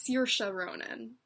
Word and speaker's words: Saoirse Ronan: So Brooklyn, this was a beautiful movie, premiered at Sundance to critical acclaim, and it stars Saoirse Ronan, which this is the correct pronunciation because we were so lucky to have her do a Q&A after the screening we saw, and it's Saoirse Ronan.